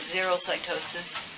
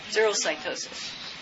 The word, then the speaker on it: xerocytosis: How do you say it? I say it, xerocytosis. Okay, this is caused by an influx of potassium. So this has a leaky membrane, but you don't have spherocytes and you don't have stomatocytes. So you call it xero. No, xerocytosis.